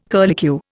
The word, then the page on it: curlicue: Indian